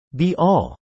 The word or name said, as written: be-all